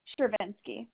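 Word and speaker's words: Shirvinski: Shervinsky. – Spell that for me. – S-H-I-R, V as in Victor, I-N-S-K-I.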